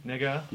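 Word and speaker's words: Negga: Ruth Nega? – Yes. – Am I saying that correctly? – You are saying it correctly.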